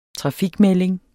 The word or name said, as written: trafikmelding